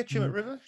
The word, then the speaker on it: Tumut River: Nomad, and I'm not sure how to pronounce this one exactly, but Tumut River. – Yeah, Tumut River. – Tumut River. Yep.